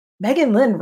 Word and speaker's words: Meghan Lynn: I'm like, Meghan Lynn rhymes with For the Win.